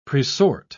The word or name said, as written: presort